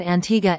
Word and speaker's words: Antigua: About Antigua and Barbuda. Antigua, pronounced Antigua, and Barbuda, Barbuda, is located in the heart of the Caribbean Sea.